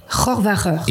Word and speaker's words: Rohrwacher: Non, CH en allemand, c'est Rohrwacher. – Rohrwacher.